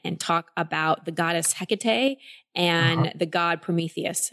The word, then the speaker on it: Hecate: You actually pronounce Hecate's name perfectly, okay, good. – Which is very unique. It is Hecate.